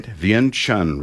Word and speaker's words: Vientiane: Brother and Sister Oldham served in Laos, let me get this right, Vientiane recently.